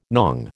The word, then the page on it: nong: American English